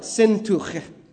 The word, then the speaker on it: Syntyche: If you really want to know how to pronounce that word, it's like Syntuche.